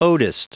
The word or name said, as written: odist